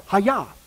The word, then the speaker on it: hayah: Now, as we saw before, that is the Hebrew word that is pronounced Hayah.